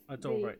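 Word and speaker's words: Adorée: how do you say her last name? René Adoré? – Adoré, yeah. It's Adoré. The adored René.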